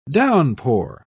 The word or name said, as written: downpour